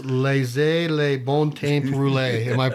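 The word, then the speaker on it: laissez les bons temps rouler: Tell me how to pronounce laissez les bon temps rouler. Am I close? – You close as I can get it.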